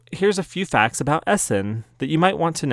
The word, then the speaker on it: Essen: So Essen, pronounced Essen, is a city in Germany.